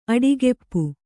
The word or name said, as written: ಅಡಿಗುಪ್ಪೆ